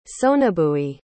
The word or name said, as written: sonobuoy